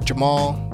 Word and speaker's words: Jamaal: And if you're on Instagram, make sure you're following me at Jamal, _Jamal.